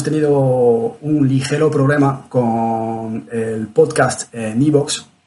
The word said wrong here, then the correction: iBooks: no sé nunca cómo pronunciarlo. iBooks. iBooks, vale, gracias.